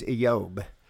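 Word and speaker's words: Eob (איוב): Its Hebrew is I-Y-O-W-B, and I understand from Strong's the pronunciation is Eob.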